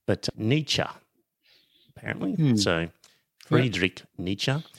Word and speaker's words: Nietzsche: Then there's Friedrich Nietzsche. Nietzsche or Nietzsche? I think it's Nietzsche, because when I went on Google how to pronounce, so you would have seen it written, dear listener, Nietzsche.